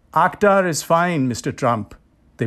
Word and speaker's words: Akhtar: "So Ak, like octopus?" – "That works." – "But is that how you say it? Where you're from? Where are you from?" – "Pakistan." – "Pakistan. – And we pronounce the name differently there." – "I'm talented. I can say it right." – "So we say Akhtar." – Father reverted to the native KH guttural sound that no white American in his experience had ever been able to master. There was a moment's silence on the other end of the line.